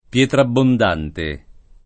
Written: Pietrabbondante

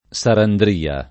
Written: Sarandria

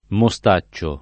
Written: mostaccio

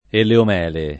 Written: eleomele

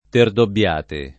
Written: Terdobbiate

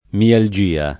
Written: mialgia

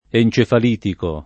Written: encefalitico

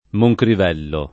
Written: Moncrivello

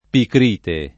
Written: picrite

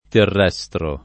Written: terrestre

